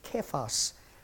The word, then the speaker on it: Kephas: The name that's used is Cephas, or it probably should be pronounced Kephas.